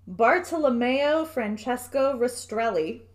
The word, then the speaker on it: Bartolomeo Francesco Rastrelli: So the Italian designer, and bear with me with this pronunciation, Bartolomeo Francesco Rastrelli.